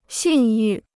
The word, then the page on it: 信誉: xìn yù